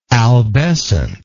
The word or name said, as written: albescence